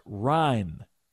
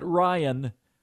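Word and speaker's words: Ryne: Ryan Sandberg, pronounced Ryan, not Ryan, Ryan. Ryan has two syllables. Ryan has one.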